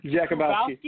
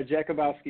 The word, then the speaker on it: Jakubowski: Jakubowski. – Did I put it right? – Yeah, Jakubowski.